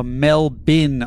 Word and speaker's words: Melbourne: It's pronounced Melbourne or Melbin, Australia, not Melbourne, Australia.